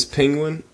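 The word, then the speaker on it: Pingwin: Okay, so my name is Penguin, and no, that's not originally spelled like you would normally spell it. It's P-I-N-G-W-I-N, alright?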